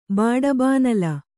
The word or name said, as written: ಬಡಬಾನಲ